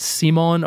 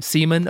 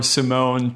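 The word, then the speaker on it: CIMON: It is called, I'm assuming I'm pronouncing it right, Simon, or it's C-I-M-O-N. Simon, okay. – Simon. So we'll say Simon or Simone to make things not awkward.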